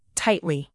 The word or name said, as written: tightly